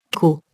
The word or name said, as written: co-